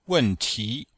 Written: wen4ti2

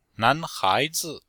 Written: nan2hai2zi5

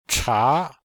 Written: cha2

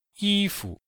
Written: yi1fu5

